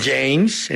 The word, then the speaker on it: James: Le tiran un balón y hay un James, aunque se escribe James, pero es James en inglés.